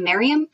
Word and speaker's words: Mariam: So, like, I pronounce my name Mariam, and I hate when people call me Mariam, and I've never liked the name, etc., etc.